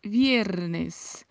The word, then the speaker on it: viernes: Esta pronúncia de viernes é uma pronúncia um pouco diferenciada, porque no espanhol o V tem um som de B. Então pronunciamos não viernes, mas pronunciamos viernes, como se quiséssemos falar a letra B, como se quiséssemos falar viernes, mas não é um B muito fechado, é viernes.